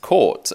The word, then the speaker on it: Court: So it's just pronounced court, as in how it's spelled, but it's obviously an unusual surname.